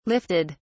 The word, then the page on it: lifted: British English